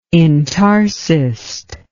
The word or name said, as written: intarsist